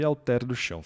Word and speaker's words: Alter do Chão: Então, aí é Alter do Chão.